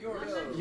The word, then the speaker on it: Youers: March 16th, Rio Youers. Is that how you pronounce it? – Yours. Yours. yours I'm yours. Yours.